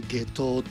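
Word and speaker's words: 夏油: 本日は下東ビレッジ株式会社共同代表の村岡陽子さんにお話を伺います。よろしくお願いします。 – よろしくお願いします。 – よろしくお願いします。 – 下東ビレッジ、ね、下東温泉、はい、夏の油と書いて下東って読むんですよね、はい、なかなかそうなんです。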